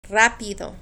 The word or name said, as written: rapido